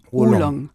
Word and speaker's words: Oolong: Dann habe ich ihn auch ganz falsch ausgesprochen. – Oolong.